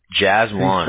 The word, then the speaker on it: Jazwan: Jazwan, right? That's a doozy, huh? – How do you spell it? – J-A-Z-W-A-N. – J-A-Z.